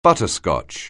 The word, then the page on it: butterscotch: anglais